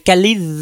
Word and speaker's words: Kalisz: Calice, c'est compliqué à prononcer. K-A-L-I-S-Z en 1813, un accord signé entre la Russie et la Prusse contre Napoléon Ier.